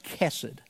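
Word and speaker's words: chesed: And the word is pronounced kesed.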